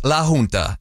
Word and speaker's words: La Junta: No, that's not Spanish. It's La Junta, and you're all saying it wrong.